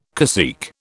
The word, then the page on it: cacique: British English